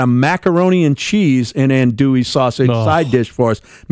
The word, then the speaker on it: andouille: and he ordered the Andyville sausage, not knowing how to pronounce the word andouille.